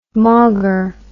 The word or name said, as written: mawger